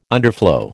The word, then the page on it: underflow: American English